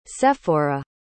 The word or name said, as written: sefora